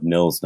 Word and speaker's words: Nils: So, and then also I have a Facebook author page, which is my full name, which is Todd Nils Nyholm, and Nils is spelled N-I-L-S, and then Nyholm is N-Y-H-O-L-M.